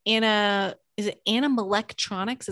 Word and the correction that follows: animatronics: Is that how you say it? – Animatronics. – Thank you, because I butchered that.